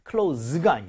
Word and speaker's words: close: 발음 어떻게 했어요? – Close. – close입니다. close가 아니에요. 얘는 답답한 동사니까.